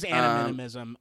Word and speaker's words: animism: It is animism.